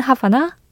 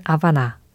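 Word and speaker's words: Habana: 어, 영어식 발음은 하바나, 원래 발음은 아바나.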